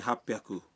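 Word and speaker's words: ہاپ بیاکو (八百): بعض آداد کا تلفظ کچھ مختلف ہے مثلاً 300 ہے سام بیاکو 600 ہے روپ بیاکو اور 800 کو کہتے ہیں ہاپ بیاکو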